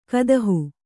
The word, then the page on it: ಕುದಿಹ: kudiha